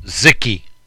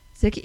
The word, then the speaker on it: Zike: Uh, research bit says it's pronounced Zicky. – Zicky, is it? – Z I K E.